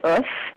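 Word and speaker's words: Oeuf: It's spelled, the French word. It's spelled like eggs? – Sorry. Yeah. O-E-U-F, I believe.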